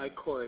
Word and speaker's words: ichor: Ichor is how it's pronounced,